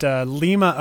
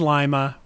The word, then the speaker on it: Lima: First of all, we pronounced it Lima, Ohio, but it is Lima.